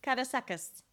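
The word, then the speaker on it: Karasakız: So it looks like phonetically Karasakiz, Karasakiz. I'm sure it's not that phonetic, Karasakiz. So now say it correctly, please. – Almost exactly right. Karasakiz. – Okay. Karasakiz.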